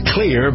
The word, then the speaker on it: Xlear: It's pronounced clear, but it's spelled X-L-E-A-R.